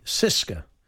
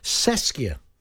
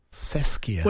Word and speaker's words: CSKA: Some people say Cisca. – Yes. – But Alan Pards, he said it's pronounced Seskia. – Seskia.